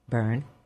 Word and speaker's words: Byrne: Next week's guest is Lorna Byrne, B-Y-R-N-E. How do you say that? – Byrne. – Is it Byrne?